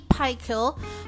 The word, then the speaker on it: Pychyl: That's why Timothy Pikel of Carleton University in Canada, who has written many books on procrastination, Google him, guys. His last name is spelled as P-Y-C-H-Y-L.